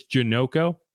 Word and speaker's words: Janocko: I figured out how to say it. It is Janoco. So in case anyone was wondering and why I kept vacillating between Janoco and Janoco, it's Janoco.